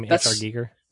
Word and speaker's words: H.R. Giger: Yeah, given props, if that's how I pronounce his name, H.R. Giger.